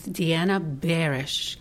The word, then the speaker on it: Diana Barash: It's Diana Barash. Close enough. – Gosh, again, close. Everybody repeats. Diana Barash. – Okay, seems like easy when you say Barash. Okay, well, how you pronunciate it? Can you spell this name? – The first name is spelled like Diana, D-I-A-N-A, and the last name is B-A-R-A-S-H.